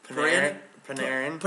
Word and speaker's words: Panarin: We're going to go Panarin. Is that how you pronounce it? – Panarin.